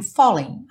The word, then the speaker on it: falling: O fo seria muito mais forte do que o ling. Mas aqui, pela métrica da música fica I'm falling, I'm falling. Uhum. E o G não é pronunciado.